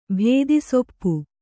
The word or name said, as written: ಭೇದಿಸೊಪ್ಪು